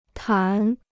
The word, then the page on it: 谈: tán